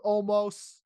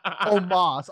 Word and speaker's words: Omos: Okay, so I'm going with Omos. – Me too. – Let me say it correctly. Omos.